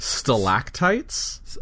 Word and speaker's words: stalactites: Stalactites? – Oh, is that how you pronounce it? I thought it was stalagmite. No? – No, stalac.